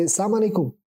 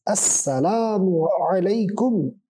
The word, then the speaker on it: Assalamu alaikum: They say, Assalamu alaikum. What is Assalamu alaikum? Assalamu alaikum, Assalamu alaikum. That's how they say it. Okay? Assalamu alaikum. Alaikum. It is Assalamu alaikum. This is proper pronunciation.